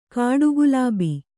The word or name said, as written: ಕಾಡುಗುಲಾಬಿ